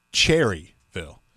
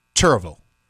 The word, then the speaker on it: Cherryville: So it's not Cherryville. – No. – People down here in North Carolina, we pronounce it Churrville.